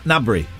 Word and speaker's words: Gnabry: Well, uh, now his name is spelled GN, but I'm assuming it's like Gnasher the dog, which mm. you two don't know. – I do know Gnasher. That we pronounce it Na- Gnabry. Yeah, but yeah, bang on actually.